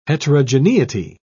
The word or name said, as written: heterogeneity